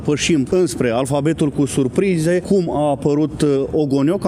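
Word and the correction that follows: ogonek: Am pronunțat bine? Sau Ogonec. – Ogonec. – Ogonec. Ogon e coada și Ogonec este diminutiv de la acest cuvânt.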